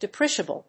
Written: depreciable

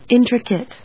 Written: intricate

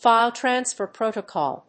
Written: file transfer protocol

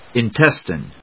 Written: intestine

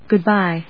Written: Good‐bye

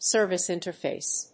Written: service interface

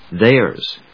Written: theirs

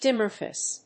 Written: dimorphous

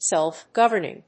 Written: self governing

